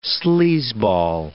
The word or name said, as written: sleazeball